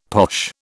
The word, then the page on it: posh: British English